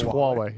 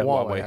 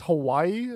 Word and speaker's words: Huawei: Is that how you pronounce them? – It's Huawei, yeah. – Yeah, Huawei, actually. – Yeah. – You don't actually pronounce the A that much. – Yeah, I know. I'm just... – No, I know, yeah. Everyone says that. They're like, is this Hawaii?